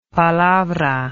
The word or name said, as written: palabra